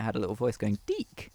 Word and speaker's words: DiC: It was produced by, it was made by Deek, D-I-C. – Excuse me? – Deek. And I think this is something that has stuck with me. – Is that really how you say it? – Yeah, because